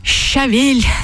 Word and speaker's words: щавель: Кстати, я посмотрела словарь русского словесного ударения. Все-таки правильно «щавель», товарищи.